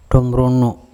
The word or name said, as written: Thumrongo